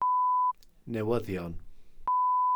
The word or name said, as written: newyddion